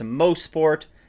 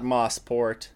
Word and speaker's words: Mosport: And yes, indeed, it is pronounced Mosport, not Mosport.